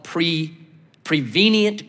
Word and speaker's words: prevenient: It's called prevenient grace. That's the right way to say it.